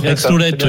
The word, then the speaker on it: Aix-Noulette: Je vais mal le prononcer. Vous êtes à Aix-Noulette, c'est ça? – Aix-Noulette, oui.